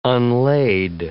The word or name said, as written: unlaid